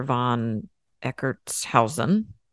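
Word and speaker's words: von Eckartshausen: So Waite responded by recommending that Crowley read the occult classic, The Cloud Upon the Sanctuary, by this guy named Counselor von Eckertshausen. I can't believe I just pronounced that right.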